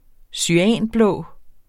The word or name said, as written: cyanblå